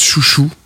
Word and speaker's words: Xiu Xiu: Un deuxième titre donc de Xiu Xiu, ça s'écrit X-X-I-U-X-I-U, mais je crois que ça se prononce Chouchou, un truc comme ça.